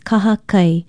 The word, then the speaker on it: kahakai: Aloha, I'm Leilani Poliahu of Hawaii Public Radio, and our Hawaiian word for today is kahakai. Kahakai is spelled K-A-H-A-K-A-I, and it means beach, seashore, seacoast, seaside, or strand.